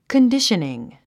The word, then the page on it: conditioning: kəndíʃəniŋ; カンディショニン